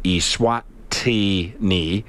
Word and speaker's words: Eswatini: So Eswatini, pronounced Eswatini.